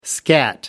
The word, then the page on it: scat: American English